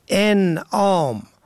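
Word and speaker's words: اِنعام: ان آم و ان آم، این دو واژه در تلفظ و معنا متفاوت است. انعام با فتح اول جمع نعم به معنای چارپایان است، اما انعام با کسر اول به مفهوم بخشش است.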